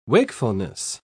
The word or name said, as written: wakefulness